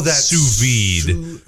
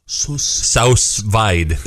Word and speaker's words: sous vide: Sous vide. – That sounds much classier than boiled in a bag. But now pronounce it as it's written. – Sous vide.